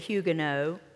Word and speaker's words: Huguenot: But it's a very prominent French Huguenot, or as we should say, Huguenot last name.